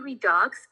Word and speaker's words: Redux: It's called Redux Makeup, but we spell it as R-E-D-U-X. So a lot of people ask me, they're like, is it Redux? I'm like, well, that's how you would spell it, but it's called Redux. It's exactly like Glossier.